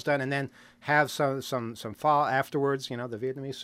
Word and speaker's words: pho: Not, pho. P-H-O is how it's spelled. So you want to say pho, but it's not.